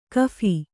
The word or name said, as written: ಗಫಾ